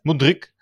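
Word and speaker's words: Mudryk: En over transfers gesproken, die Moedrik, Moedrik, hoe spreek je dat uit? Moedrik. Moedrik.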